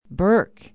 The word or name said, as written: burke